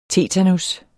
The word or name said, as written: tetanus